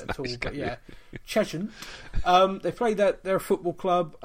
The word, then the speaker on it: Cheshunt: They play at Cheshunt. I don't know if I'm saying that right. Am I saying that right? – Yeah, Cheshunt. Cheshunt. – Yeah, Cheshunt. Yeah.